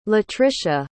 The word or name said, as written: latricia